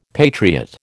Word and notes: patriot: American English

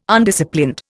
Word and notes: Undisciplined: Indian

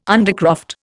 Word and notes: undercroft: Indian

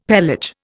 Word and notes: pelage: Indian

